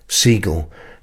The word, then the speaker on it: Siegel: It's pronounced like the bird, Siegel.